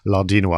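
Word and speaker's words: Lardinois: And they have a key writer called Frederic Lardino. I'll pronounce it the French way.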